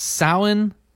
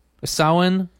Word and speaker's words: Samhain: So anyway, Samhain is pronounced Samhain. Samhain, and so we wanted to get that right because we have a lot of witches that listen to the program.